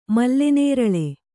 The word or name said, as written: ಮಲ್ಲೆನೇರಳೆ